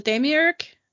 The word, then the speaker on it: demiurge: A Demiurge? Is that how you say it? – Yes.